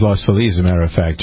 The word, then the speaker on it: Los Feliz: Got to say it like a local, buddy. – I know. Los Feliz. – It's like Houston and Houston.